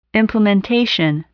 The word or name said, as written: implementation